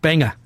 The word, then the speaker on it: Banga: The album's called Banga. Unless they're pronouncing it different. It's B-A-N-G-A. – Like the city in Maine? Banga.